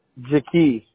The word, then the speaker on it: Jahkeith: No, my first name is spelled J-A-H-K-E-I-T-H. – Oh, wow. How do you pronounce that? Jakeith? – Jakeith.